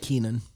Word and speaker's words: Keenan: Maynard James Keegan, whatever. – Boom. – He was like— Keenan.